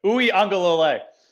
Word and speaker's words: Uiagalelei: Uiagalelei. Did I say it right? – No, not even close, but it's okay. Everyone will know his name soon enough, so we'll get used to it. Uiagalelei. – Uiagalelei.